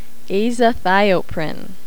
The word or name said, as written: azathioprine